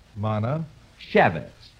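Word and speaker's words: Manischewitz: Now, let's break it up into syllables and work on it. – Okay. – Now, look, repeat after me. Mana. – Mana. – Shevitz. – Shevitz. – Mana Shevitz. – Mana Shevitz.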